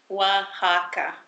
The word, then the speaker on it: Oaxaca: Yeah, it's Oaxaca. – Oaxaca? – Like W-A-H, Oaxaca. – But it's spelled O-A-X-A-C-A.